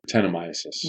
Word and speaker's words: Brettanomyces: Am I saying it right? – Brettanomyces.